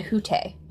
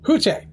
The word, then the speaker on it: Hütte: So let's talk about the food that we had at, it's the Hütte. – Or the hut. It's spelled H-U-T-T-E. – When I called, they said Hütte. – Hütte.